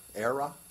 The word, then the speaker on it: Aira: ERA. It's spelled A-I-R-A. So it's the word air with an A on the back.